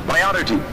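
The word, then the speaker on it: priority: اولا کا معنی حق کا تقدم, اولا کا مقدم, اولا کا معنی پی آر آئی او آر, کس طرح پرونانس کرتے ہیں اس کو؟ پرائیورٹی.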